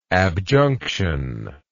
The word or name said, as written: abjunction